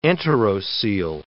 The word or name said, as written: enterocoel